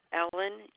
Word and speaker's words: Ellen: Ellen, E-L-L-E-N. Middle initial is C as in Carol. The last name is D'Alessandro, D-A-L-E-S-S as in Sam, A-N as in no, D-R-O.